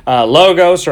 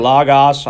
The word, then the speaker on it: Logos: Logos or Logos. I don't know which kind of Greek snob you are. If you are one, Logos, Logos, whichever you want to pronounce that Omicron on, okay?